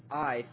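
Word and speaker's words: ice (εἰς): Our denominational friends say that the Greek word EIS, pronounced ice, means because of.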